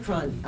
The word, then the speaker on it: Cron: Lisa Cron. Her last name is spelled C-R-O-N.